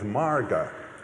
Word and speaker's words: Marga: The elementary is known as Marga, M-A-R-G-A with a long A, which means path.